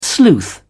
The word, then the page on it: sleuth: British English